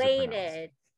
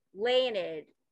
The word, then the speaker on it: Leinad: How is Leinad. Leinad.